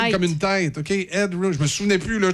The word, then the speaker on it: Headroom: Max. Endrum. Je, je me souviens plus si je le eh, prononce comme faux. – Max Headroom. Oh my God! – Ah oui, c'est comment? C'est Headroom. – Headroom.